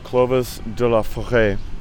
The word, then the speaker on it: Clovis de la Forêt: He's got this band called Clovis de la Forêt, Clovis of the Forest. My mother is French, so I do pronounce it like that.